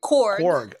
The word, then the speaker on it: Korg: We do see a lot of, what's his name, Krog? That little rock monster looking dude? – Yes. – So Korg.